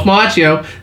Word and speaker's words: Macchio: where you get to control your is that Ralph Macchio? Is that how you pronounce that? Yeah, all right, Ralph Macchio.